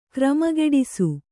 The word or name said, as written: ಕ್ರಮಗೆಡಿಸು